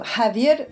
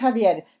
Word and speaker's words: Javier: I am very pleased to interview Javier Bahir. How do you say in English? Javier Bahir? – That's perfect, actually. You said it in Spanish.